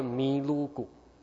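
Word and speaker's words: ミルク: 牛奶怎么说你知道吗？Milk，他念作米卢古。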